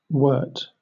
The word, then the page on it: wort: British English